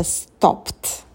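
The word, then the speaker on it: stopped: Или «стоп». Слово заканчивается на глухой «п», и прошедшая форма – это «стопт». Прибавили звук «т».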